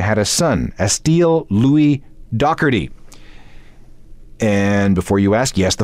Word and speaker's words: Doherty: Oh, by the way, Doherty is how you do pronounce Pete Doherty's last name. That's the way the British do it.